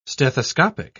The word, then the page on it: stethoscopic: British English